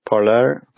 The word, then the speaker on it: Parler: And Parler, how you pronounce it, like French parlor, how to talk.